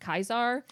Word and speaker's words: Caesar: The letter C in ancient Greek / Roman never made the soft sound. So every time that you like watch a period piece like this and they call Caesar, it was actually pronounced Kaisar, which is where Kaiser comes from, which is the literal interpretation of the word.